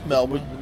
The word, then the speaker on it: Melbourne: Was it Melbourne? Did I say that right? – Yeah, we... Melbourne. We just call it Melbourne. – Melbourne.